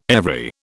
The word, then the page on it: every: British English